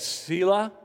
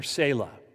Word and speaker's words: Selah: It can be pronounced two ways Selah or Selah. That's up to you.